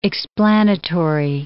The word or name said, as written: explanatory